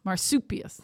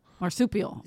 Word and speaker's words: marsupial: Marsupius. Marsupial? Marsupius. How you say it? – Marsupial? – Marsupial.